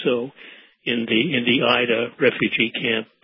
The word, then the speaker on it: Aida: Is it the Aida refugee camp? – Aida. We pronounce it Aida camp.